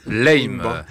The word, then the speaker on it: Lime: Alors Lime Band, c'est comme ça que ça se prononce? – Lime, exactement, pour être précis.